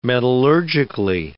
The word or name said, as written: metallurgically